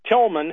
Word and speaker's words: Tilghman: But what Mr. Tillman, whose name is spelled T-I-L-G-H-M-A-N,